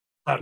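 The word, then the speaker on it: Tár: Tar. – Ah, se, se tiene que llevar el Oscar esa pinche película, está buenísima. Con acento en la A.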